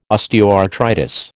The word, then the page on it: osteoarthritis: American English